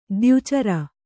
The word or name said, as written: ದ್ಯುಚರ